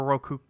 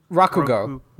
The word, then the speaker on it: Rakugo: Is it Rakugo or Roku? – Rakugo. Rakugo.